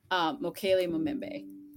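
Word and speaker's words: Mokele-mbembe: Mokele Mbembe.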